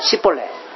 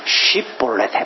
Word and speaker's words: 쉽볼레: 그렇게 그 지역의 억양적인 특성 때문에 쉽이라고 하는 발음을 잘 못해요. 그래서 이 에브라임 사람들은 쉽이라는 발음을 잘 못해가지고 씨볼레 씨볼레 심지어는 이것을 쌍시옷으로 발음해가지고 좀 욕같이 들리지만 씨볼레 씨볼레 이렇게 발음하는 거예요.